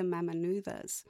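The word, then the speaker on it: Mamanuca: And yes, in Fiji, the C is pronounced with the TH. So it's actually the Mamanuthas.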